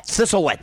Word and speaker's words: Thistlewit: Thistlewit. – Thistlewit.